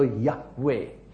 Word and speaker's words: Yahweh: But the original word is YHWH, and the vowels are not inside. So to pronounce it, we add an A and an E, so Yahweh.